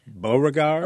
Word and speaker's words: Beauregard: Beauregard. – Oh, Beauregard. – B-E-A-U-R-E-G-A-R-D. Just like Beauregard at the plantation.